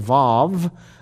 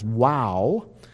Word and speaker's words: vav: Sometimes it's pronounced as vav. I prefer the pronunciation as wow.